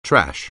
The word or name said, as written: trash